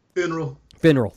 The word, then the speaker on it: Finral: Finral.